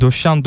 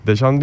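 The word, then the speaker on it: Deschamps: Türk spikerler özellikle Fransızca'da böyle E'ler Ö okunur mantığıyla döşen döşen diye vurguluyorlar. O yanlış bir telaffuz, onu bir söyleyelim. İki Saint Joseph'li olarak orada E'den sonra S geldiğinde onu deşan diye okumak gerekiyor.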